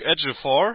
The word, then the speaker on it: Ejiofor: Aber wir reden über Duke Ejiofor. Habe ich ihn jetzt richtig ausgesprochen? – Nein. Duke Ejiofor. Also ist im Endeffekt wie Edge und dann noch Öfor.